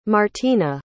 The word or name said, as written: martina